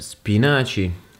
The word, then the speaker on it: spinaci: And we also have spinaci. – Which means spinach. And like in dolci, the last syllable is spelled C-I. So we get spinaci.